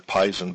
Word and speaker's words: Pishon: Or Pison in the King James Version, with the way that the pronunciation symbols are there, Pison, but it's Pishon in Hebrew.